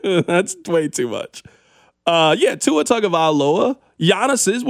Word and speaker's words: Tagovailoa: It took me a little bit, but I got Tua Tagovailoa.